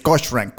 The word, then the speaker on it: Cochran: Porque es Cochran. Cochran. – Ajá, ándale. Cochran. – C-H, C-O-C-H-R-A-N.